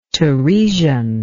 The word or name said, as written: teresian